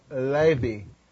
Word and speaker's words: Levi: And she named his name Levi, and the way it's pronounced in Hebrews is Levi.